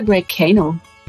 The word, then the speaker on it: Graycano: Ist eigentlich Grey Kano oder Grey Kano? Habe ich mich letztens gefragt. – Das, äh, ja, es ist eigentlich Englisch, auch Englisch ausgesprochen, Grey Kano.